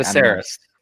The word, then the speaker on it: Viserys: How do you say his name? Because you're better at this than me. – Viserys.